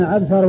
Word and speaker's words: عبثر: وفرق بين عبثر وعنبر إنما هو بالنقط، وأما الشكل فإنها متفقة، والفرق إنما هو بالنقط والاختلاف في الحروف بين الباء والتاء وبين النون والباء، الباء والتاء، الباء والتاء في الأول عبثر، والنون والباء في الثاني عنبر، فهذان